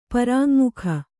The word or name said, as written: ಪ್ರಾಙ್ಮುಖ